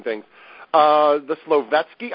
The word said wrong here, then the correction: Solovetsky: Islands, is that how it was? – Yeah, well, pretty close. Solovetsky.